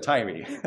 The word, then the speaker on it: Tyre: But someone came to me, and there's one of the nations that Isaiah prophesies to is spelled T-Y-R-E. That's the last one. And I pronounce that as Tyre, and one of my friends came up after the sermon, and he was saved and started going to churches in the South, and he says, in the South, we pronounce T-Y-R-E as Tyree, and I was like, oh, I've never heard of Tyree, but I'll do my best.